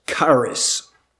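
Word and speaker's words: charis (χάρις): And so we'll spell it K-A-R-I-S, charis, charis.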